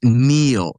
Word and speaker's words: Niall: It's pronounced Neil. Yeah, that's right. I called him Niall in that debate.